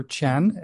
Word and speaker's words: Chien: My name is Peter Chen. It's spelled C-H-I-E-N.